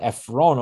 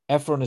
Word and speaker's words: Ephron: So mostly, almost always, or perhaps every time, Ephron is written with this vav, Ephron. As I said, the vowel O is used, you use a vav to express the O sound.